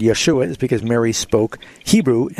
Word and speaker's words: Yeshua: If you were going to say Jesus' name in Hebrew, it would be Yeshua.